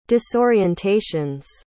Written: disorientations

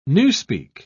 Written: Newspeak

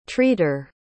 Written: treater

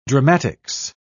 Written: dramatics

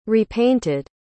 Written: repainted

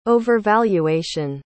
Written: overvaluation

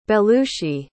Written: belushi

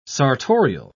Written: sartorial

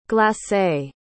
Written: glacé

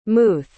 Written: muth

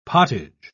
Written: pottage

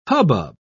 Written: hubbub

